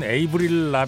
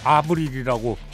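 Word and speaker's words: Avril: 보통 영어권에서는 에이브릴 라빈이라고 하는데 캐나다 출신 아니에요. 프랑스계입니다. 그래서 본인은 아브릴이라고 발음을 하는데 네.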